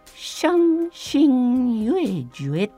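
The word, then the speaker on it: 傷心欲絶: はい、そして今回はそうなんですよ今回はですね台湾のバンドで、えー、傷傷ね傷つくの傷心、はい、欲それから絶対の絶と書いてシャンシンユエジュエっていうですね、うん、バンドをご紹介します。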